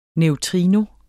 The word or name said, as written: NEUTRINO